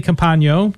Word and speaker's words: Campagno: It's an evening with Emily Campagno.